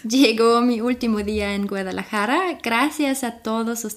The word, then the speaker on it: Guadalajara: Guadalajara? – Ja. – Wirklich? – Guadalajara. – Guadalajara.